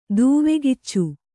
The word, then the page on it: ದೂವೆಕಿಚ್ಚು: kannada